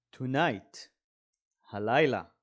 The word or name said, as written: tonight